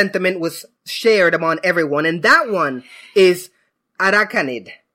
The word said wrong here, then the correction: Araquanid: And I hope I pronounced that correctly. But Araquanid. – Araquanid. Araquanid. – Like it's a pun on aqua and arachnid.